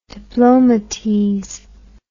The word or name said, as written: diplomatese